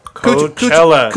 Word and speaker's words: Coachella: Kind of speaking on Coachella, like, how do you say it? – Coachella.